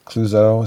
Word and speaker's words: Clouzot: And I love, Henry Clouzot.